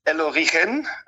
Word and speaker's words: El Origen: El Origen, in Spanisch ausgesprochen, steht für Herkunft.